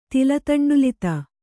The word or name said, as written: ತಿಲತಂಡುಲಿತ